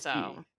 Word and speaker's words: GIF: Is GIF-y. – Obama took a side Obama in 2014 announcing his official position is that it is pronounced GIF like a hard G, like a grape.